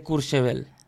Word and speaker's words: Courchevel: A ver, ¿qué podemos contar hoy de, de Courchevel? – Bueno, Courchevel no es una ciudad. – Ah, primero lo has pronunciado muy bien.